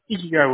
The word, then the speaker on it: Ikigai: I'm sorry, how do you pronounce that? – Oh, Ikigai Woodworks. – Ikigai, I did good.